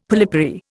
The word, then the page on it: polypary: Indian